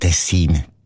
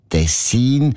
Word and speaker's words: Tessin: Aber der SRF-1-Hörerin Regula Stern-Grieser von Locarno ist aufgefallen, dass gewisse Leute statt Tessin Tessin sagen, also Betonung auf ein Meh, mit einem kurzen I.